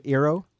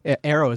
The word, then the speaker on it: Eero: Eero is, am I saying it right? Of course. I say this, I call this show name, I call all these things wrong. – User Air? – Yeah, User Air, and it's Eero instead of Eero.